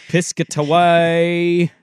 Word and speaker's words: Piscataway: At Rutgers Stadium in Piscataway.